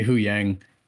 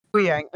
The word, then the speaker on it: Huyang: How do you pronounce that? – I usually say Huyang. – Huyang, okay.